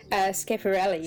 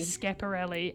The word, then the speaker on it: Schiaparelli: Schiaparelli. – Schiaparelli.